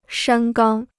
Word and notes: 山冈: shān gāng

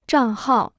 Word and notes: 账号: zhàng hào

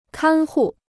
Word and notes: 看护: kān hù

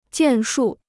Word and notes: 建树: jiàn shù

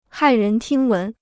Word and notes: 骇人听闻: hài rén tīng wén

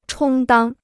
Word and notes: 充当: chōng dāng

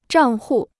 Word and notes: 账户: zhàng hù

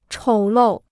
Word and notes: 丑陋: chǒu lòu

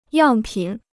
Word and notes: 样品: yàng pǐn

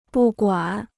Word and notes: 不管: bù guǎn

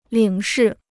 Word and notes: 领事: lǐng shì

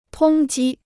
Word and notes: 通缉: tōng jī

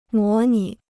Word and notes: 模拟: mó nǐ

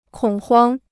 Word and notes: 恐慌: kǒng huāng

